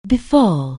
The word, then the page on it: befall: anglais